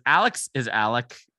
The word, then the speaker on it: Alex: It, but Alex is Alec and Murdaugh is Murdoch. So that's just how South Carolina rolls.